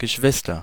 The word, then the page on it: Geschwister: [gə.ˈʃvɪs.tɐ]